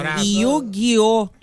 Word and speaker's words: Yu-Gi-Oh!: Yu-Gi-Oh!